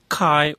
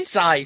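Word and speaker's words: Cy: Kai or Sai? How do you pronounce that? C-Y? – Sai. – Sai, come on.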